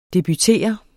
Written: debutere

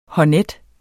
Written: honnet

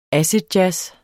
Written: acidjazz

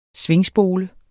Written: svingspole

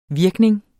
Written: virkning

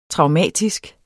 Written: traumatisk

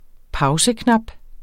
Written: pauseknap

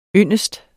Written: yndest

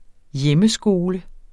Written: hjemmeskole

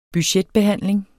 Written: budgetbehandling

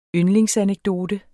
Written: yndlingsanekdote

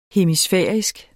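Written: hemisfærisk

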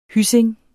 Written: hyssing

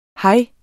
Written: hej

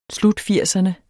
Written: slutfirserne

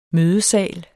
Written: ISNET

